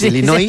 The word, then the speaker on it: Illinois: Es un estado de Estados Unidos que se pronuncia así. Es Illinois, sí, según tú.